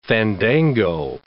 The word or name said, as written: fandango